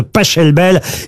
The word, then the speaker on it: Pachelbel: Ça s'écrit P-A-C-H-E-L-B-E-L. – Mais il adorait l'Italie. Ah, mais par contre, Laurent, s'il est allemand... – Canelloni, ça se... pas Schelbel. – S'il est allemand, ça se dit Pachelbel.